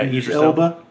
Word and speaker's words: Idris Elba: Is that how you say his name? – Yeah, Idris Elba. – Idris Elba.